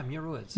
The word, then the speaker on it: Muir Woods: Muir Woods? – Yeah, Muir Woods.